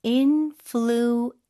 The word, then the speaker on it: influencer: 그 P 발음 쓰시면 안 되고 F 발음 쓰셔야 돼요. Influencer. 네, 부드럽게 influencer.